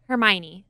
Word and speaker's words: Hermione: Is it Hermine? Hermine? – Hermione.